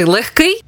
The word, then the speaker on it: легкий: Як правильно? Легкий чи легкий? Наголос на другому складі. Легкий.